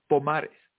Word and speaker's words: Pomares: Say it again, Mauricio. We're practicing here. – Mauricio Pomares. – Pomares. Yes. So stress the middle syllable in last name.